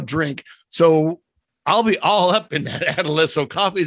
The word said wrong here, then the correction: Adesso: Is it, how do you pronounce that again? – I think it's Adesso. – Adesso, okay.